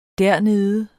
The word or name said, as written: dernede